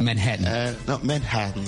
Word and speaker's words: Manhattan: Mais une fois arrivé sur place, la prononciation change. Quand on dit Manhattan... – Manhattan. Yes. – Here in Manhattan.